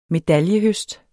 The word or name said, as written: medaljehøst